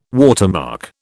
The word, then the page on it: watermark: British English